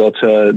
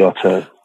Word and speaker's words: auteur: She pronounces The Auteur the way you do, like the American way, but I pronounce it the European way, The Auteur.